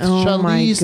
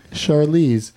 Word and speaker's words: Charlize: You did with Charlize Theron. – Yeah. I loved it. I loved it. – I did not pronounce that right. Charlize.